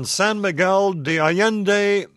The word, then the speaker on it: San Miguel de Allende: And, Zorba, our next caller is a listener in San Miguel de Allende, Mexico. Hi. – Hey there. You pronounced it pretty well there, Tom.